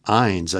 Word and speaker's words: Ihns: Indianola Superintendent Ted Ines, it must be pronounced, it's spelled I-H-N-S,